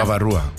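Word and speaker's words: bavarois: ¿Cómo será? – Babarúa. – Babarúa. – Babajúa sería. – Se escribe Babarois. – No, sí, pero sería Babarúa.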